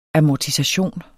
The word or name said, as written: amortisation